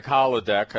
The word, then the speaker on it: Hladek: And uh, you just pronounce it like holodeck, and all of you Star Trek uh, fans will remember it that way.